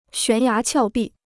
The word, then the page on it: 悬崖峭壁: xuán yá qiào bì